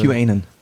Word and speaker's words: QAnon: Kanon-Fans, oder Kanan, Kanan, weißt du die für? – QAnon. – QAnon spricht man das? – Ja, ich glaube.